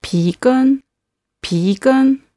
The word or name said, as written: pigen